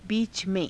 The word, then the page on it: biica_me~: hindi